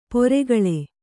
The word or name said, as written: ಪೆರ್ಕಳ